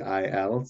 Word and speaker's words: Weil: It's a French pronunciation of a German looking name. So it's Weil, Simone Weil, very interesting thinker.